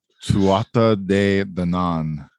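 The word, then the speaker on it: Tuatha Dé Danann: Tuatha De Danann.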